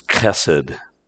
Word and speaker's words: chesed: In the Hebrew, that word is chesed. C-H-E-S-E-D. Chesed. It's a hard K sound in the back of your throat.